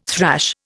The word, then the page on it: thrash: Indian